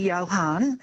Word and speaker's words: Johann: It's my full name is actually Johann, spelt with a J, as in Sebastian Bach.